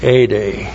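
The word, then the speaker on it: ade (ἤδη): And then ade, the adverb, that's long E, D, long E, ade.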